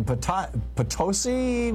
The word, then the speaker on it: Potosi: Jason in Potosi, Missouri. Am I saying it right, Jason? – That is correct.